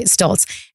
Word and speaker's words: Stoltz: Stoltz? – Yes, that's right. Stoltz.